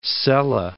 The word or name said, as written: Celle